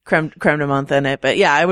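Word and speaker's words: crème de menthe: A little bit of vanilla ice cream and some, is that how you pronounce it? Creme de menthe? – Or, that's the snooty way of saying it. You can say creme de menthe or whatever.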